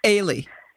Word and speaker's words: Ailey: Ailey.